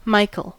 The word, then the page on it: Michael: American English; /ˈmaɪ.kəl/